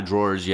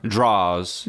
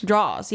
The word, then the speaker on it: drawers: Keep saying drawers like fucking kitchen drawer. – Draws. – Draws. Yeah, it's like draws. If you're gonna say it, you have to say it correctly.